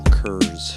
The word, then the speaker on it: Kurz: I'm Joel Kurz. – And we're back again with you guys. – Mm-hmm. – Can I just say that my name is spelled K-U-R-Z? – Not Kurz.